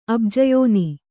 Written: ಅಬ್ಜಯೋನಿ

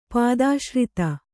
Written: ಪಾದಾಶ್ರಿತ

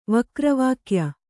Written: ವಕ್ರವಾಕ್ಯ

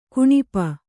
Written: ಕೌಣಪ

